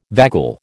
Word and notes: vagal: American English

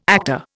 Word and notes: actor: Indian

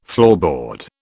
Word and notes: floorboard: British English

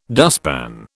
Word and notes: dustpan: British English

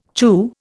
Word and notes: chew: Indian

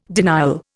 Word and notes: denial: Indian

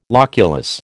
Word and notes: loculus: American English

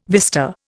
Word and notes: vista: Indian

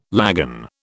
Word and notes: lagan: British English

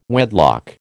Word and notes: wedlock: American English

